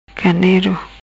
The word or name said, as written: Ganero